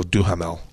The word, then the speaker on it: Duhamel: What's Duhamel? – He's he was a world-class superbike. – Oh, I pronounce it different. I go Duhamel.